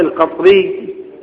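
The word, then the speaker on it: القطرية: قطرة هي القطرة القطري أو القطري نسبة لنوع الثوب، و هكذا تلفظ بكسر القاف وتسكين الطاء. أما قيل بأنها قطر فتح القاف والطاء وهي من بلاد البحرين، وهذا غير يعني مرجوح وليس راجحا.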